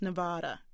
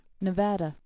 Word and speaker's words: Nevada: "Now that Senator Reid is the majority leader, everyone should know how to properly pronounce Nevada." Well, he actually won't be the majority leader until January, but nonetheless, thanks for sending us this audio link to the correct pronunciation. Let's take a listen. – Nevada.